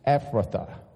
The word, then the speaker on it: Ephrathah: When you get to the hard word, it's pronounced Ephrathah, okay?